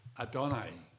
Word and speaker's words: Adonai: The second word referred to here is the word Adonai. I spell it now for you. A-D-O-N-A-I.